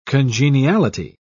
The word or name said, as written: congeniality